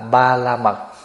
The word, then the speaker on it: Ba La Mật: Tiếng Phạn đọc là Ba Ra Mi, tiếng âm mình đọc là Ba La Mật.